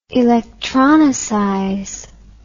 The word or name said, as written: electronicize